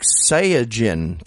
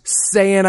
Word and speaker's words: Saiyan: He was God, not happy about that word's pronounced Saiyan. Jesus. Yeah. You just lost us like half our listeners. – I actually heard it was pronounced like Saiyajin, technically. – It's pronounced Saiyan. I will fight anyone who disagrees with me on this.